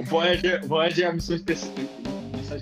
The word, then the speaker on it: Voyager: Cara, o, o, o Voyager, Voyager, não, esqueci já como é que fala o nome, mas beleza, eu sou meio. – Voyager.